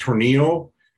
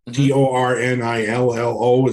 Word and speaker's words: Tornillo: I believe it's pronounced Tornillo. Tornillo. It's the town in Texas in the studio where they recorded that record.